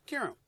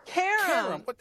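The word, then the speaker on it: Qerim: Kiram. – No, it's not Kiram. It's Karam. – Karam.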